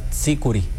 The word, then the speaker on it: tzícuri: Que está mal dicho porque se debería de pronunciar tzícuri. – El tzícuri.